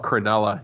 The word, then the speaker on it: Cronulla: Am I saying that right? Cronulla? – Oh, Cronulla.